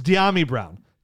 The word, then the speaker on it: Dyami: I, the funny part is you got it right earlier and were correcting me. – I told you how to say his name and I just messed it up. – You overthought it. That's all right. – It's Diami Brown.